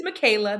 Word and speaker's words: Michela: So it's Michaela, but it's spelled M-I-C-H-E-L-A.